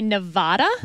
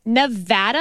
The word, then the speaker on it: Nevada: Because a lot of politicians would say Nevada. – Wrong. – And that is wrong. – Wrong. It is Nevada. And I had to train myself to say Nevapple so I could pronounce the A right.